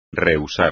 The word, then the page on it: rehusar: espagnol